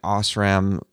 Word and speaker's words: Osram: I've seen Osram and Lifix bulbs. I don't even know if I'm pronouncing them right. – You are. – Osram is the one with the little module that plugs into the middle of the bulb.